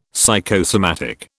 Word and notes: psychosomatic: British English

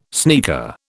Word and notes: sneaker: British English

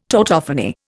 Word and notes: tautophony: Indian

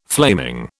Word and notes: flaming: British English

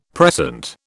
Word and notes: precent: British English

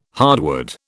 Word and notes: hardwood: British English

